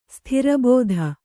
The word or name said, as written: ಸ್ಥಿರಬೋಧ